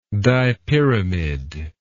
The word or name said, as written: dipyramidal